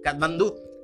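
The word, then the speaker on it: Katmandú: Uno de los más graves que registró fue de magnitud 7.8 en Kathmandu. no lo puedo pronunciar. Kathmandú. Ahí está.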